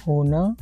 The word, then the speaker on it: hona: Pronounce this verb hona, h o n e, hona.